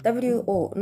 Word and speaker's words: 我: えー、発音記号は WO の第三声ですので低く沈む音として、えー、発音します。